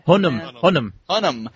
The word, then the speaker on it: Hunnam: How do you pronounce his name? Hunan? – Hunnam.